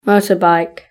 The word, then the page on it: motorbike: British English